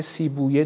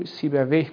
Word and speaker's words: سیبویه: سیبویه داریم، سیبویه گفته میشه تو عربی ولی فارسی سیبویه تلفظ میشه. بعضی تلفظ ها در عربی و فارسی با هم فرق میکنه.